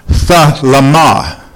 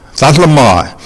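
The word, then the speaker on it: thalama (θέλημα): And so the word pleasure that is used in the King James Version, it's the Greek word pronounced thalama, thalama. And so we look at that Greek word, and here's how my Greek Hebrew dictionary defines it.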